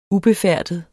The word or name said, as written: ubefærdet